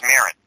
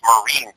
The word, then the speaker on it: Marin: I didn't know that's how he pronounced his last name. I was always led to believe it was Marin. It's Marine, by the way.